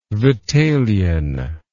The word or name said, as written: Vitalian